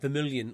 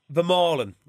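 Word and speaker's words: Vermeulen: Vermaelen, that's a better pronunciation of it.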